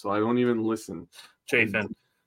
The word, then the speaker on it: Chafin: I took Andrew Chaffin. Chaffin? Am I saying his name wrong? Chafin.